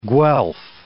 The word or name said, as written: Guelf